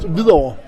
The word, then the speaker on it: Hvidovre: Luke, I have used the whole day to learn you pronounce Hvidovre.